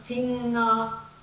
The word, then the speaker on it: Finger: Wange, Schlange, Finger. You never pronounce the G after the N. It's always soft and nasal.